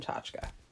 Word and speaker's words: tchotchke: American is, it's spelled tchotchke, but you pronounce it in Russian tchotchka.